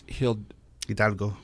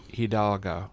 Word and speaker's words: Hidalgo: And I mispronounced it. It's Hidalgo. – Hidalgo. – Thank you. – Got to roll your R's.